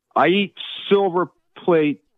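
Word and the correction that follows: Silver Palate: Silver palette pasta sauce.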